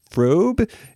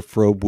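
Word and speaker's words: Fröbe: Well, the actress' name was Gert, and I want to say the pronunciation is Frobe. It's one of those European names where the O has the dots over it, the umlaut.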